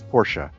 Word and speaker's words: Porsche: That's great that we even have like a clip of Randy explaining how to pronounce Porsche.